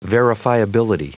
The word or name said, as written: verifiability